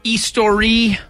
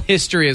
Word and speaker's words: Histoire: The band is Belle Histoire. – Histoire! – It's French. – It's French. – I don't know, man. Belle History is what I say, but it's actually not. Histoire.